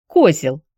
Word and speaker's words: Козел: «Как-как?» «Козел. Фамилия такая, чешская, с ударением на «о».